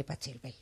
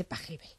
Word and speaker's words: Pachelbel: El otro día me enteré, por ejemplo, aparte de escuchar música, que no se dice Pachelbel, que se dice Pachelbel.